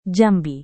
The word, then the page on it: jambi: Indonesian